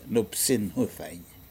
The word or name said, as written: nupsin hudain